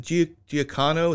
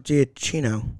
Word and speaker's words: Giacchino: So I'm now really scared because I already know that you really like, is it Giacano? Is that his name, the composer? I just asked you how to pronounce his name earlier. – Yeah, it wasn't Giacano. – Giannino? – Giacchino. – There you go. I think that's right. But either way, you said it, not me. – Giacano. Okay, but you said it wrong twice.